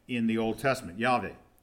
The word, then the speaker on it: Yahweh: In Hebrew, it's pronounced Yahweh. Okay, Yahweh or Yahweh, it's depending on which scholar you listen to.